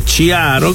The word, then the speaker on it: chiaro: En italiano es chiaroscuro así que ella lo, utilizó con el juego de palabras de chiaro, que se escribe CH, y en italiano se pronuncia como si fuera una Q o una K.